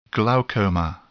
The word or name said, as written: glaucoma